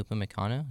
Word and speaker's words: Upamecano: So like, how do you pronounce his name? Upamecano. Did I say that right? – Yeah.